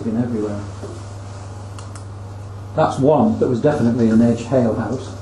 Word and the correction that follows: ale house: Oh, said it wrong there, hale house. Ale house.